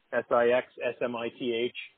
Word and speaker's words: Sixsmith: It's six like the number and Smith like the name, Sixsmith.